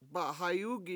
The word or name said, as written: ba7hayUgi7